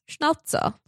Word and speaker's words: Schnauzer: I think it's meant to be pronounced Schnauzer. – Schnauzer. – But I will say Schnauzer, not to embarrass myself. It sounds like a kind of like fancy soda water. – Yes. It kind of sounds like seltzer.